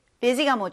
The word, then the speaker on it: busy: Busy가 뭐죠? 바쁜. 여기에서 sy여서 약간 c, busy 하고 싶을 수도 있는데 s 발음이 아니라 좀 z 발음에 가까워요. z. 그래서 busy 하면 됩니다.